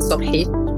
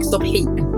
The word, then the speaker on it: Sobhi: Yeah, Nameen Sobhi. In Arabic, Sobhi.